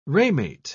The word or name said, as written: ramate